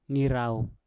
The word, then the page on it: nirauw: Mpur